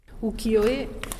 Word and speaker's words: Ukiyo-e: È un termine che è costruito con tre caratteri cinesi che sono appunto uki, yo e, che significano immagini del mondo fluttuante.